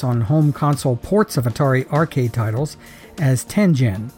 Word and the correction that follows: Tengen: The correct pronunciation for the name of the company was Tengen, with a hard G. The name, as I mentioned, is a term derived from the Japanese tile game Go, and in Japanese, with very few exceptions, a G is always pronounced with a G sound, not a J sound.